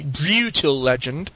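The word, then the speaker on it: Brütal Legend: And since it has that little umlaut above the U, it has to be Brutal Legend. That's how it's supposed to be pronounced. Not Brutal Legend. Brutal Legend.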